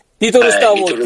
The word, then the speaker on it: 宇宙小戦争: リトルスターウォーズって言うんですか。